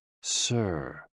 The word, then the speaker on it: SI: SI, pronounced like sir, with a slow exhale, which is associated with the lungs.